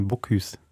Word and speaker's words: Bocuse: Ich habe sogar falsch ausgesprochen. Ich habe es nicht nur falsch verstanden oder gar nicht verstanden. bocuse Ja.